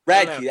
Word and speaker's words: Radke: Radke, that's it.